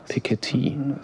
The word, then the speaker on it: Piketty: Piketty wird das ausgesprochen.